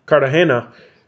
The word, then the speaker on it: Cartagena: And he says something like, oh, well, our friends in Cartagena. Am I saying that right? – Cartagena.